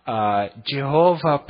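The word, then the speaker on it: Jehovah: И некоторые это озвучивают как Иегова, кто-то говорит Яхве, Джехова, по-английски и так далее. И причина тому, что мы не знаем, как точно произносить это имя, заключается в том, что его столько долго не произносили, что люди забыли, какие там нужно гласные вставлять.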